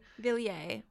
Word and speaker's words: Villiers: Villiers.